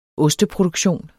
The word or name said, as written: osteproduktion